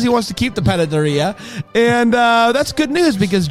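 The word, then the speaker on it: panadería: It's a panera. – Panaderia? – Panaderia.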